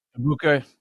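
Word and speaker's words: Egbuka: and now they have Emeka Abuka. Is that how you say his name? – Abuka, yeah.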